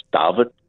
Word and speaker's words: David: A young man from the Eastern Cape called David, as it's pronounced in Afrikaans, David Stuurman.